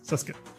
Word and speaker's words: Cisca: Siska. It's Siska. I keep mispronouncing it.